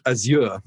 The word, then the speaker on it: Azure: Yes, over here, that word is pronounced Azure.